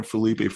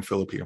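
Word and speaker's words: Phillippe: I've been calling Ryan Felipe forever, and then I heard someone say Ryan Philippi. I've been saying it wrong this whole time, but I'm an idiot.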